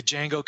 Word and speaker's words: Django: Jawa Unchained, the D is silent. So, you know, with the Django coming out and the Jawas in the game, it's really taken on a life of its own.